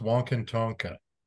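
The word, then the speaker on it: Wakan Tanka: It's pronounced Wonkintonka.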